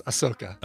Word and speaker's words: Ahsoka: The H comes before the S, so it's Ahsoka.